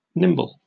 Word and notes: nimble: British English

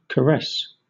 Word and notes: caress: British English

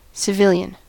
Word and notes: civilian: American English

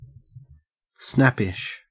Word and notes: snappish: Australian English

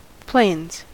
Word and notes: plains: American English

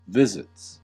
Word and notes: visits: American English

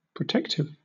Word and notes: protective: British English

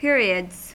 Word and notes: periods: American English

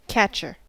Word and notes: catcher: American English

